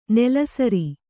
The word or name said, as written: ನೆಲಸರಿ